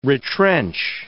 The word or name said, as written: retrench